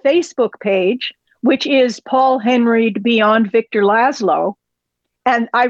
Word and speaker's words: Henreid: Again, it's We Love Paul, H-E-N-R-E-I-D. Follow her on both. – I think he pronounced it Henreid, didn't he? – It's Henreid.